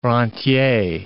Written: rentier

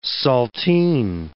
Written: saltine

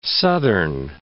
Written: southern

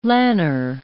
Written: lanner

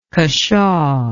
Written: cushaw

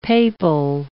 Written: papal

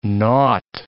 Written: nought

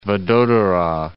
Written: Vadodara

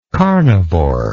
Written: carnivoral

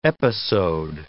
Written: episode

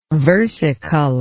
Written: versicolor

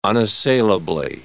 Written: unassailably

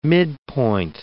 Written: midpoint

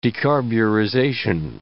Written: decarburization

